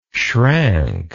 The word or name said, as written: schrank